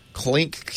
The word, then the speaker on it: Cleeng: Kling? Clink, C-L-E-E-N-G, sell your videos live and on demand.